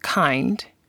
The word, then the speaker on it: kind: It's spelled like kind, but pronounced kind.